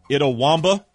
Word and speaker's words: Itawamba: Itawamba. – Okay. – Actually sounds right.